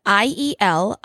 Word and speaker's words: iel: Yeah, because Le Petit Robert chose to add the word IEL, it's pronounced like yell.